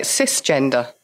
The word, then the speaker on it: cisgender: Because the other one that you mentioned is how do you say it? Cisgender. – Yeah, that's right.